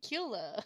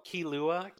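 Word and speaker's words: Killua: We're introduced to the veterans of the exams, who's had multiple attempts, and a boy with a skateboard named Kula. I probably said that wrong too. – Oh, the kid so, with the skateboard. – Yeah, it's the white haired kid. – Oh, Kilua? – Kilua.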